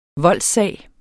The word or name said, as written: VOLDSSAG